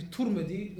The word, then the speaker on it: Tirmidhi: So his name can be pronounced in three ways. Tirmidhi, Turmidhi, and Tirmidhi. The most famous one is Tirmidhi bikasrat ta. But if I say kama ja'a fi Turmidhi, then I said the Sheikh is making a mistake.